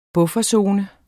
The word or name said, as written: bufferzone